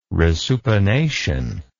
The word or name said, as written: resupination